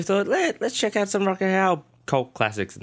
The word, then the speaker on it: Rutger Hauer: It's a Rutger Hauer movie. Did I pronounce his right name? – Rutger Hauer.